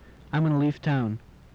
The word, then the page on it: leave town: English